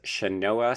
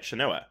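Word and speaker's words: Sianoa: It's Shanoa Smith McPhee. – Oh, good. So I was right. Shanoa.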